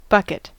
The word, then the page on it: bucket: American English